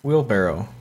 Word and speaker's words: wheelbarrow: I think you say barrow. – Wheelbarrow. – Wheelbarrow. What do you say? – I say barrel. Wheelbarrow. – It's spelled barrow. – Yeah, I know. It just came to me that I'm pronouncing it incorrectly.